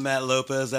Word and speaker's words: Matt Lopez: Hey, I'm Matt Lopez. L-O-P and the rest is easy.